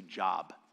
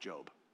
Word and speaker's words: Job: If you want to read it later, you'll see Job, not Job, Job, spelled the same.